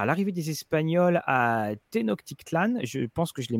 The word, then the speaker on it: Tenochtitlan: alors en Awa, c'est Tenochtitlan, c'est un, un CH. – D'accord. Tu dire euh, Ténoc, comme ça, au moins, hein, c'est bon. – Voilà, Tenochtitlan, de conçu pour offrir de nombreuses possibilités pour des groupes mixtes et de changer le devenir de cette région.